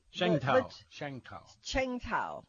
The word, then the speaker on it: Tsingtao: Cheng Tao.